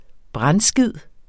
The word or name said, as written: brandskid